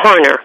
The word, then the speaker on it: Harner: And did I say that correctly? It's Michael and Sandra Harner. – That's right. That's right.